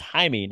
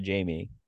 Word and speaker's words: Jaime: Hashtag it's pronounced Jaime, not Jamie.